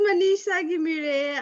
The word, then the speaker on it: Manisha Ghimire: This is Manisha Ghimire.